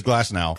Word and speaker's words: Glasnow: I get him and Jeff Luhnow confused so much for some reason, and I mispronounce him half the time. It is Glasnow.